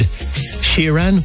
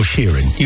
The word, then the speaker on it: Sheeran: Well, if you want to know that, nobody better to ask than Ed Sheeran or Sheeran. You decide.